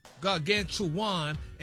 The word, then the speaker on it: gargantuan: Oh, sound like you missed a syllable there, sir. – Oh, gargantuan. – No. No, sir. – Maybe you should just make some more videos with you with different clothes on with Stephanie Mills music, like you've been doing. – Look at the breakdown of the word. – It's pronounced gargantuan.